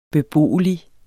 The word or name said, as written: beboelig